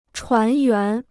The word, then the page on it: 船员: chuán yuán